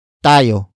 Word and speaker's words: tayo: Word for word it's ngingitian, tayo, ng, kapalaran.